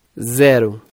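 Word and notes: 0: Brazilian Portuguese

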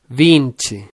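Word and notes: 20: Brazilian Portuguese

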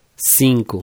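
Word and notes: 5: Brazilian Portuguese

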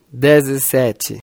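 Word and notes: 17: Brazilian Portuguese

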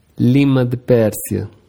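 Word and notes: lima-de-persia: Brazilian Portuguese

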